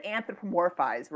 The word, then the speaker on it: anthropomorphize: Well, and hey, speaking of pronunciation, I have to say I said anthropomorphize right. Anthropomorphize. I don't know, but I heard it on This American Life, and I was like, I think I said it right, and you corrected me. – No, you knew all the letters that were in it, but it was kind of like alphabet soup. You didn't get them quite in the correct order.